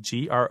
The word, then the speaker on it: Grothe: That's how you say it, Grothe, called I Never Met a Four I Didn't Like.